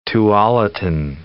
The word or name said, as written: Tualatin